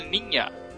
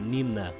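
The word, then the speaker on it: Niña: Pequena canelada, os navios de Colombo são Pinta, Ninha e Santa Maria, Aninha se pronuncia Ninha. Errinhos comuns entre os brasileiros sem muita intimidade com o castelhano. É, falar Nina.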